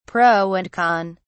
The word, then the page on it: pro and con: British English